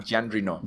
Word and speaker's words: Jean Reno: John Reno, creo se llama el actor. – Jean Reno. Jean, Reno.